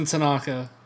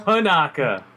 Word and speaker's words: Panaka: Oh, Captain Tanaka. Tanaka, Panaka? – Panaka.